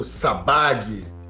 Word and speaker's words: Sabbagh: é, que, aliás, como é que fala o nome do Danilão? É Dan Sabag ou Sabá? – Danilo Sabag. Sabá é TH.